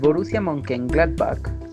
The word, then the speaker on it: Mönchengladbach: Este, el Borussia Mönchengladbach.